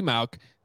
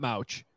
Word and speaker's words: Mauch: But it is Cody Mauch, not Mauch.